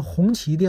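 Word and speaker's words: 宏碁: 不是宏基电脑，这个谢谢啊，嗯，这个东西呢，一直好像不是光我说错哈，我看好像，嗯，百度上很多人问都是打宏基电脑，这我一直都没注意，谢谢你啊，叫红旗电脑，其实也挺好记啊，和咱们红旗轿车发音是一样的，红旗电脑，啊，这位听友呢也非常的感谢，那再说一位啊，再说一位是咱们。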